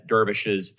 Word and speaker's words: dervish: And I think it was the Darvishes. I never pronounced this correctly. – Like a whirling, like a dervish? Like the Islamic warriors? – Yes, dervish. Yes.